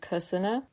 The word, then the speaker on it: Kaesana: I believe it's pronounced more like Kusana, with the K sound way faster.